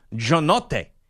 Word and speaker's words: Giannotti: Because, for example, I've said this before, the way you're supposed to pronounce my last name, like if I go to Italy, it's Giannotti. That's how you would say it.